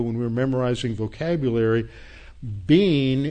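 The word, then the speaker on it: bean (בין): Now, the word discernment in the Hebrew, that's translated discernment from the Hebrew, is the word bean in Hebrew, pronounced like our word B-E-A-N.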